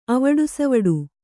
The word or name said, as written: ಅವಡುಸವಡು